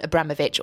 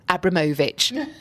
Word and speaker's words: Abramovich: My other overindulgence is simply a message for Chelsea and to Roman Abramovich, or Abramovich. As you are meant to say his name.